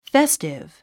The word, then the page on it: festive: féstiv; フェスティブ